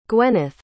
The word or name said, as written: gwyneth